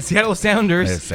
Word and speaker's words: Seattle Sounders: El, Seattle Sounders.